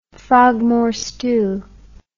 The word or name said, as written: Frogmore stew